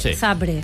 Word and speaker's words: Zabrze: ¿Por qué pronuncia Zabrze? – Porque es así.